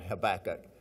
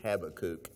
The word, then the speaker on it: Habakkuk: I always called it Habakkuk, but Alistair Begg says it's Habakkuk.